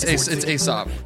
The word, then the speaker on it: Aesop: Aesop. – No, it's Aesop. – Yeah. – I said it wrong. – Like the Fables.